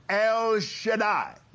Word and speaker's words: El Shaddai: He actually said, the way it is written, I am El Shaddai. Now that's the correct pronunciation, not Shaddai and not Shadda or none of that.